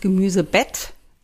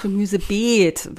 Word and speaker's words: Beet: Wir haben ja ein ganz langes E und nicht das Gemüsebett, sondern das Gemüsebeet.